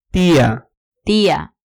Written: tia

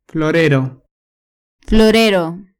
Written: florero

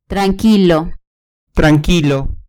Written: tranquilo

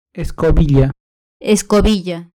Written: escobilla

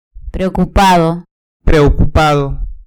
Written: preocupado

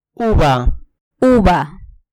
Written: uva

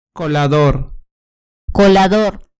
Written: colador

